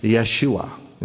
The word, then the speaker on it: Yeshua: Pronounced in that language, Yeshua in the Hebrew.